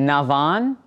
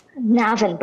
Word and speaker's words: Navan: who joins us from the little town in Navan. Did I say that right, Sinead? – Navan, but close enough, Navan, Imran.